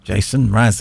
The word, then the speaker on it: Jason Mraz: Jason Mraz, there we go.